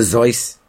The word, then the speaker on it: Seuss: Pronounce it correctly, please. – Dr. Zeus.